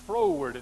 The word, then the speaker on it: froward: It looks like forward, but it's froward. It's spelled F R O W A R D.